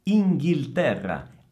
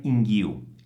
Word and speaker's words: Inghilterra: Inghilterra. Attenzione al suono della L, non è una U, non è inghiu, è inghil, inghil, Inghilterra.